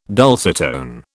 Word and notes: dulcitone: British English

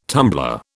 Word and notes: tumbler: British English

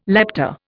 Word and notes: lepta: Indian

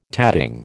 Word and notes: tatting: American English

